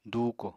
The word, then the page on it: dùuko: apatani